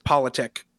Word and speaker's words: Politic: It's almost correct. Don't worry. There's not a single teacher I've ever had who's ever said it correctly. So it's much more boring than it looks how it's spelled. It's just Politic.